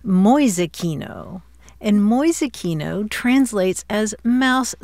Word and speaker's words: Mäusekino: M-A-U-S-E-K-I-N-O. Mäusekino.